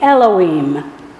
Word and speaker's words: Elohim: And that first word would be Elohim. In English, the way we would spell it, E-L-O-H-I-M.